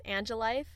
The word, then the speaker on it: Annjelife: Yeah, my cosplay or my Instagram handle is Angelife, and that's spelled A-N-N-J-E-L-I-F-E.